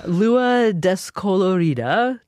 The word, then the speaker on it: Lua Descolorida: This is you actually singing Lua Descolorida. Did I say that right? Oh, I probably did. – Yeah, that's right.